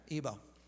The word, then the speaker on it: Igbo: Igbo? Is that what you said? Is that how you say it? – Igbo. – Yeah, Igbo. Okay, Igbo.